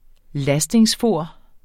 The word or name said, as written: lastingsfor